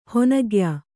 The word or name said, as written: ಹಣ್ಣುಗಾಯಿ